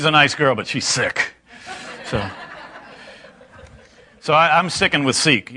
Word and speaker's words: Sikh: S-I-K-H, I think is how it's spelled. She actually pronounced it sick,